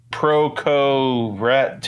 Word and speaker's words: Proco Rat: And the only pedal your friend has to fatten the sound is a Proco Rat 2. Did I say that right? – Yes, you did.